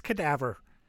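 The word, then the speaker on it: cadavre: So they repeat the move with un cadaver, mm-hmm. right? And again, I know I just said that wrong. In French. Sorry. It's cadaver.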